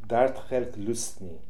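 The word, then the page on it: Darthelhlustnian: Russian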